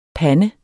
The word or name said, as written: pande